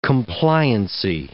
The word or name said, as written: compliancy